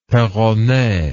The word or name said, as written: perronet